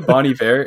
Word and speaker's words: Bon Iver: It sounds pretentious when you say it like I do, Bon Iver. It really, really does. But I think it's supposed to be said that way.